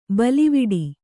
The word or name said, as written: ಬೇಲಿವೀಡು